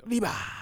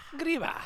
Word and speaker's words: Griba: They pronounce Griba the same way we do. – Griba. – Griba.